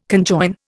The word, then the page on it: conjoin: Indian